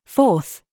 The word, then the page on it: FIFE: British English